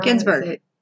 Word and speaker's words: Ginsburg: Ginsburg.